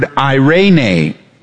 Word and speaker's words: eirene: It's the Greek word eirene. It's spelled this way. E-I-R-E-N-E.